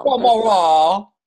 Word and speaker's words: Balmoral: Balmoral.